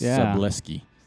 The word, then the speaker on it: Szablewski: But fourth place was Zachary S, because I can't pronounce his last name, S-Z-A-B-L-E-W-S-K-I. So it's like Brewski, but with an L and an S, so it's Subleski. Let's go with that.